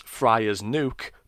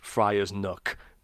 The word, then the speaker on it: Friar's Nook: which I would pronounce Friar's Nuke, but which is obviously supposed to be pronounced Friar's Nook by someone with an actual proper accent.